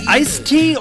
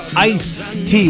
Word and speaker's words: iced tea: Do you say iced tea or ice tea with no D? – Well, according to the Merriam-Webster Dictionary, both pronunciations are fine. Now, it is actually supposed to be iced with a D, but iced tea has become common because we're lazy. We leave sounds out of our everyday words.